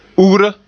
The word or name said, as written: ugra